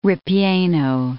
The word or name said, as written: ripieno